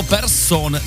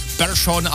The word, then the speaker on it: Persson: Místo Persson nebo Larsson jsme slyšeli Persson a Larsson. A fanoušci se ptají, proč? No, protože právě takhle se to ve švédštině opravdu správně vyslovuje. Žádný Persson, žádný Larsson, ale Persson, Larsson.